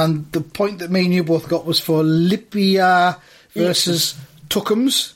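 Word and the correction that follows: Liepāja: Let me pronounce that properly for you. It's Lapija versus Tuckums. – Tuckums! – Tuckums! – Tuckums! – 5-0 to Lipija. – Lapija.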